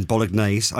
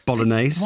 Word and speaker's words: bolognese: Right, so the beef at the moment is how I just pronounced the G in bolognese. I do apologise. Bolognese.